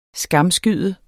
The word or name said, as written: skamskyde